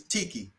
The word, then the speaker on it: Tiki: My name is Tiki. I broke it down. My name is spelled T-I-K-I, right?